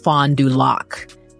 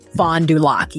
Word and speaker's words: Fond du Lac: And just as a point of clarification, Wayne said he thinks it's pronounced Fond du Lac, the name of the tribe. – Just I was FYI. Struggling with that. Fond du Lac band. – Fond du Lac, yeah.